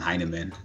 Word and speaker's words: Hyneman: So if you just search my name, Sean Heineman, my name is spelled S-H-O-N, Heineman, H-Y-N-E-M-A-N.